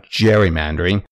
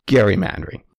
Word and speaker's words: gerrymandering: And no, it's not gerrymandering, as almost everyone calls it today. It's gerrymandering.